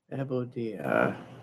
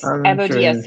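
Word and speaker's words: Ebudae: I don't know how to pronounce this. Ebudia. It's E-B-U-D-A-E. – Ebudia. – Anyways, Ebudia.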